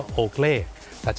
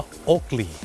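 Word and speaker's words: Oakley: คนไทยออกเสียงว่าโอเกลแต่เจ้าของภาษาไม่ได้ออกเสียงว่าโอเกลครับเขาออกเสียงว่าโอเกลี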